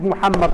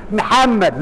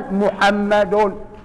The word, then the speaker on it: محمد: يا الله، بس خلي بالك معظمنا وانا منهم بنغلط في النطق بتاع اسم الحبيب محمد صلى الله عليه وسلم. معظمنا بيقول محمد، معظمنا بيقول محمد، يا عم محمد محمد. لا، نطقها الصحيح كما جاء في القرآن، محمد محمد بضم الميم الأولى، محمد محمد صلى الله عليه وسلم.